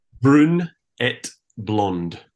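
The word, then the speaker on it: Brune et Blonde: How do you say that correctly? – Brun et Blonde. – Oh, I did say it right.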